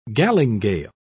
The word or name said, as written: galingale